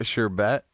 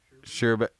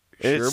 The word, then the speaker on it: sherbet: A sherbet. Sherbet. – Sherbert? It's not, is it pronounced Sherbert? Because there's not an R at the end. Okay? It's Sherbet. – Spelled S-H-E-R-B-E-T. It's Sherbet.